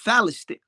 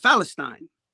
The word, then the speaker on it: Palestine: So it's really no P. There's no P. There's no pronunciation of the of the letter P. Mm-hmm. P is like a silent. Yeah. So it pronounced as a f. So it's uh, Philistine. Uh, Palestine. – Well, tech, well, so for us, it's Palestine in English. In Arabic, it's Palestine. Right.